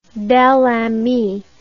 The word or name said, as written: belami